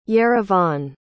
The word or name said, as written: yerevan